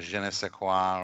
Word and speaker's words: je ne sais quoi: I think that gives it a little more je ne sais quoi, or whatever you want to say.